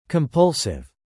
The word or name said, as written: compulsive